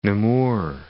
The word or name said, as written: Namur